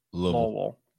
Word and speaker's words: Louisville: You say it like there's no vowels in it. Louisville.